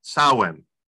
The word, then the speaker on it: Samhain: And I'm not sure why we call it that, but it's been a rude awakening for me to realize the correct pronunciation is Samhain.